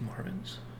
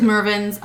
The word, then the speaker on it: Mervyn's: Mervyn's, wasn't it pronounced Marvin's? – No, I think there's a Marvin's also, maybe. But that's different. – It was Mervyn's.